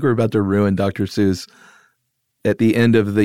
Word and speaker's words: Seuss: So, we are talking, we keep saying Dr. Seuss. Everybody knows him as Dr. Seuss, but apparently the correct pronunciation is Seuss.